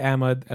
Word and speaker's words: Ahmed: And I would just say Ahmed and like let them sort of mangle my name.